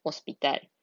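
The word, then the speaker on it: hospital: 例えば、病院っていう単語のホスピタルは最後が L で終わるので、そういう時は最後の音節にアクセントが置かれます。ホスピタルのタルのところです。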